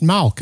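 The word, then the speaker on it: Malk (מלך): The word for king in Hebrew. In biblical times, it was pronounced Malk, and we write it M-A-L-K.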